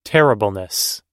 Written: terribleness

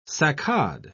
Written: saccade

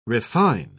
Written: refine

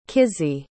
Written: Kizzy

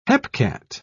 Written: hepcat

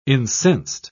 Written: incensed

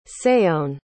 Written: saône

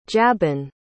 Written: jabin

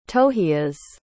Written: tohias

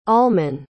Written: almon

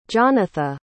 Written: jonatha

